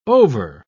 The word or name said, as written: over